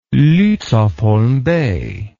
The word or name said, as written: Lutzow-Holm Bay